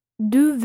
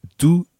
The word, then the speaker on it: d'où: Okay, so we've got d'où viens-tu and d'où venez-vous. The first word in both these sentences is the same. It's d'où, d apostrophe O U with an accent on the U.